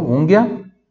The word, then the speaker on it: unghia: Se escreve U-N-G-H-I-A. Então agora G H, Então I fica com o som de Gui. Gui, Gui, tá? Gui, de Guilherme, Gui.